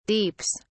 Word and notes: deeps: British English

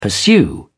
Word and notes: pursue: British English

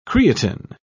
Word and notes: creatin: British English

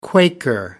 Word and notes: quaker: American English

